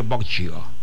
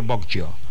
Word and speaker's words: Boggio: Algunos lo pronunciarán Emilio Boccio. Sí, debe ser así, de un apellido italiano, Emilio Boccio.